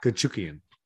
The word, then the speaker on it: Chookagian: Kukuchigin. Kuchukian. Oh, that's how you say it. Kuchukian.